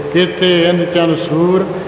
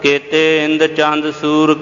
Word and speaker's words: केते इंद चंद सूर: उंज कई बार ऐसे भी उच्चारण किया जाता केतिया कर्म भूमि मेर केते केते तू उपदेश केते इन चन सूर केते केते मंडल देश ना एक केते उधर एक पास ऐसे जिमें उच्चारण किया जा रहा शुद्ध समझना है